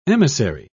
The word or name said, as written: emissary